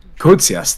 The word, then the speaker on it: Kotsias: Then our new Greek, you know, 19 guy, Kotsias, excuse me if I mispronounced. – Kotsias. – Thank you.